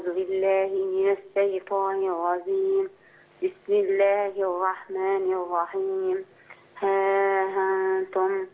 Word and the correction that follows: ها أنتم: لا خلي بالك التسهيل معهوش مد، يعني تنطق. بصي أيوة، ها أنتم.